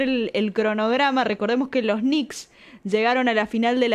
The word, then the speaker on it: Knicks: Y San Antonio Spurs, el ex equipo de Manu Ginóbili, se enfrenta con los New York Knicks. Obviamente con la asistencia de la pronunciación de Juan, y vos me corregirás. – New York Knicks, sí. – New York Knicks.